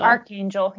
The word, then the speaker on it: Archangel: Dean says, did I say Archangel or is it Archangel? No, it's Archangel. – Archangel, yeah. – Archangel, okay. I was like, because it's spelled arch.